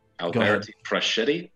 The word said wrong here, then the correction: Alberto Fresco: Alberti Freschetti or something like that. – Alberto Fresco. – Yeah. That sounds right.